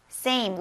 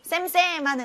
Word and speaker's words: same: same. 우리 쌤쌤 하는데 쌤 아니라 same. a 발음을 꼭 해주세요.